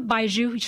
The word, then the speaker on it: baijiu: And he pulled out a bottle of, and you're going to help me pronounce this, Chinese baijiu? – Uh-huh, baijiu, sure. – Baijiu, yeah. Uh-huh. – That's how I pronounce it.